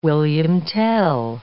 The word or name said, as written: William Tell